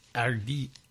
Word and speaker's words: Hardy: Hardy, I don't think you pronounce the H. I think it's Hardy, whatever.